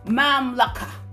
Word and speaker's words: mamlakah: Listen, that word kingdom in Matthew 6:33 has a Greek word called basila, and that is spelled B-A-S-I-L-E-I-A, basila. That word kingdom is equivalent to the Hebrew word mamlaka, and that is M-A-M-L-A-K-A-H.